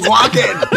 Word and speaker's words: Walken: That's my Christopher Walker. – Christopher Walker? – He can't even get his name right. What do you mean you killed him? – Walken.